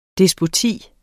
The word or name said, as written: despoti